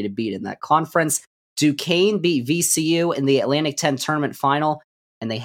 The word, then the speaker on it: Duquesne: D-U-Q-U-E-S-N-E. It's Duquesne is how you say that university's name I have learned recently.